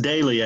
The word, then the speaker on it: Doelle: It's pronounced Daily, actually. – Daily. That's a weird way of spelling it. – Yeah, it's German.